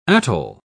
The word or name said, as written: atoll